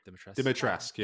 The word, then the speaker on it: Dimitrescu: Dimitrescu, yes.